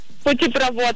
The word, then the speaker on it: путепровод: Это сложное слово, Рената, образованное от двух других. А в таких, как известно, ударение всегда падает на второй корень. А он тут вот. Следовательно, правильно говорить путепровод.